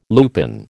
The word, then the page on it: lupine: American English